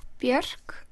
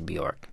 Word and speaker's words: Björk: Even though most English speakers say Björk, it's not really Björk. – It's Björk. – Björk. Björk. – Yeah. I usually say it rhymes with jerk. You know, someone's a real jerk.